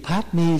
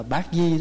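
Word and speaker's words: padme: Bây giờ chữ padmi là gì? Có khi mình đọc là bát di đó.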